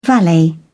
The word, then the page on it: valet: British English